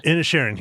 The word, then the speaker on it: Inisherin: It's called The Banshees of Inisherin. – Inisherin, yes. Yeah. Close. Yeah. That works. Okay. That's how I think you pronounce it. That's what it sounded like on the film.